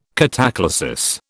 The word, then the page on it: cataclasis: British English